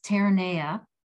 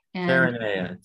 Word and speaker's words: Terrania: And it is a little bit difficult to pronounce our resort. It's kind of like Where's Waldo? So we keep changing how we pronounce, but it is pronounced Terrania. – Terrania.